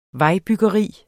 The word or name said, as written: vejbyggeri